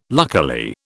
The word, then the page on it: luckily: British English